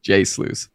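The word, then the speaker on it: jsluice: And somebody actually replied and was like, this is awesome, but how do I pronounce the name? And so he replied, J-Sluice, S-L-O-O-S-E, J-Sluice.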